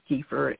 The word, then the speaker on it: kefir: It's spelled K-E-F-I-R. It's pronounced kefir, and some people pronounce it kefir.